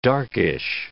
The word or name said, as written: darkish